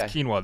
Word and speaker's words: quinoa: It's pronounced quinoa, though.